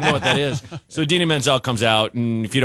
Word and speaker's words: Idina Menzel: Oh, that's pronounced like that. When I mentioned that, Menzel, thank you.